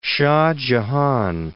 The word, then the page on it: Shah Jahan: Shah Ja.han